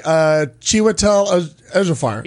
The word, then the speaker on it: Chiwetel Ejiofor: Then, because that's a great movie with Chidawar El-Jafar, whatever you say his name. – I've already gotten out of it. – Yes. – Oh, no. You're right. Chiwetel Ejiofor.